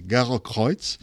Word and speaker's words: Gerokreuz: en alemany s'anomena Gerokreuz, jo ja fa temps que no vaig en Alemanya, però es pronuncia així, Gerokreuz,